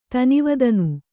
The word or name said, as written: ತನಿವದನು